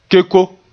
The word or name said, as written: keko